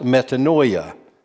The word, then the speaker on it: metanoia: The original word is pronounced metanoia.